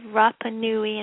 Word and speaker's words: Rapa Nui: On Easter Island, I think it's called Rapa Nui, and forgive me if I'm pronouncing it wrong, there were...